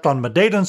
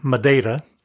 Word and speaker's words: Madeira: You'll notice I've been saying Madeira instead of Madeira, the usual pronunciation in English. After all the years on the island, I unconsciously use the Portuguese pronunciation.